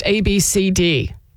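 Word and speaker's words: Abcde: It's spelled A-B-C-D-E-E. So it's pronounced A-B-C-D. That's how it's pronounced. A-B-C-D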